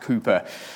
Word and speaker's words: Cowper: It's spelled C-O-W-P-E-R, but apparently it's pronounced Cooper.